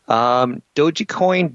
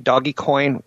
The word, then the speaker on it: Dogecoin: Dogecoin, Doggycoin, whatever you want to call it, it's pronounced both ways, hits an all-time high.